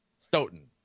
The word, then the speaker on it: Stoughton: Stoughton.